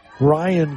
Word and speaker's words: Rian: Coming into bat here is that Rianne? Ryan Carr, R-I-A-N, is how you spell it.